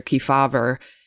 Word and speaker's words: Kefauver: which you have a chapter about Senator Kefauver.